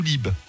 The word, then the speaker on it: Lieb: L-I-E-B, donc Lieb ou Lib. – Très bien. – Les deux versions, voilà. Il est de Pau, je sais pas si à Pau on prononce Lieb ou Lib. – Dans le sud-ouest, on prononce toutes les lettres.